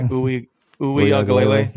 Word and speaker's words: Uiagalelei: You know, I personally think that DJ Uiagalelei, I think I said his name right, Uiagalelei.